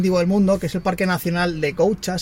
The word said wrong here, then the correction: Gauja: si no recuerdo mal. Un segundo, que lo tengo aquí. De Gauja. Se escribe Gauja.